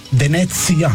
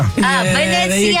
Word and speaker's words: Venezia: Okay, how do you say Venice in Italian? – Venice? I feel like it's just the same. I think that's a trick question. – Venezia. – Venezia. Okay, well, there you go.